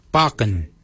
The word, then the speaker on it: baginy: For beard, we say bakin. Bakin is spelled B-A-G-I-N-Y.